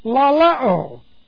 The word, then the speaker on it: laleo: Two, distinct from another word translated to speak, laleo. L-A-L-E long O.